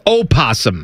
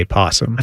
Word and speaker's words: opossum: Is it possum or opossum? – It's opossum. – Because I've heard it both ways. – Well, it's spelled opossum, but most people just say possum.